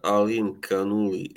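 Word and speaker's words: Alimkhanuly: I can't pronounce the last one. – Janibek Alim Kanuli.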